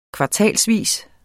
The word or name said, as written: kvartalsvis